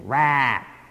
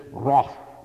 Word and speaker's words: wrath: Now, that's the American pronunciation, wrath. The English, wrath.